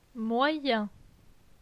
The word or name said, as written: Moyen